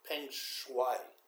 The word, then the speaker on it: Peng Shuai: Peng Shuai.